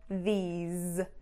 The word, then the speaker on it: these: And I don't know if they're trying to say this or these. So make sure this, short I sound, ending in an S, is, this. These, long E sound, ending in a Z, these.